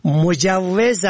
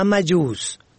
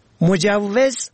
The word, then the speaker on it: مجوز: نطاقی آن را نادرست تلفظ کرد. در جمله‌ای گفت آغاز کار بعد از اخذ مجوز کاری ممکن است، در حالی که این کلمه مجوز است نه مجوز. مجوز سند اجازه و اسناد مربوط به آغاز کار است.